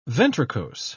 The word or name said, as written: ventricose